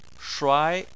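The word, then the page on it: shuai2: tone 2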